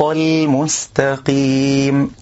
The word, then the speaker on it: المستقيم: كذلك من الأخطاء تفخيم اللام من كلمة المستقيم. البعض يقول اهدنا الصراط الطل بتفخيم اللام، والسبب في ذلك حرف الطاء من كلمة الصراط، فإنه يأتي مصحوبا باللام من كلمة المستقيم في النطق. اهدنا الصراط البعض يفخم من أجل ذلك، بل نقول اهدنا الصراط المستقيم طل طل بترقيق اللام.